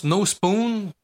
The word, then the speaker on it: Nospun: Pour ceux qui connaissent pas ça, premièrement, leur nom, No Spoon, c'est N-O-S-P-U-N, mais ça se prononce No Spoon, qui veut dire pas de cuillère en français, et c'est une référence au film La Matrice.